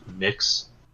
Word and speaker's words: Mx: So the prenomial is MX. It's pronounced mix.